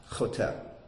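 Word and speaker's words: choteh (חתה): It's the word choteh.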